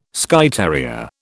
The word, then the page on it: Skye terrier: British English